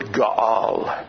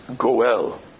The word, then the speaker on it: Ga'al: Now, in the Hebrew, the word for redeem is the word Ga'al, sometimes pronounced Goel.